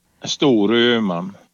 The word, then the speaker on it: Storuman: Storuman.